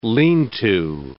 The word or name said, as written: lean-to